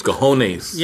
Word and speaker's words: cojones: It's cojones. – Yeah. – That's how you say it.